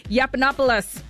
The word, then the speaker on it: Yiannopoulos: Also, Milo, how do you pronounce his name? Milo Yiannopoulos. There you go. Yiannopoulos, you guys, he has been detained by ICE in Louisiana.